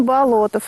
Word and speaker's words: Болотов: Ну, как говорят, меня сегодня поправляли, что Болотов. Правильно говорить Болотов. – Совершенно верно. Александр Петрович Бердышев нам все-таки доказал, что правильно нужно говорить Болотов.